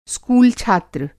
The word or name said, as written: स्कूल छात्र